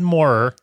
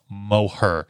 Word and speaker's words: Moher: Well, thanks to our interview with Garvin uh, from Northern, well, Northwestern Ireland, I can pronounce this correctly. So, my first high is the Cliffs of Moher. I would have said Moher, yeah, but I'm gonna try it with my best uh, Moher.